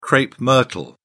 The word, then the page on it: crape myrtle: British English